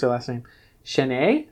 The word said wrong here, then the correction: Chrétien: I think? – Yeah, that would be Aline Chrétien, right? – Very good.